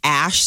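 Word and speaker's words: Æ: In Scandinavian, it's a part of their alphabet, and it's pronounced Ash.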